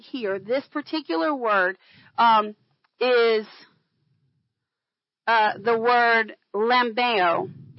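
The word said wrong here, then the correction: lambano (λαμβάνω): It's L-A-M-B-A-N. The emphasis is on the B-A-N, long O. So it's lambano.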